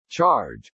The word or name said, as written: charge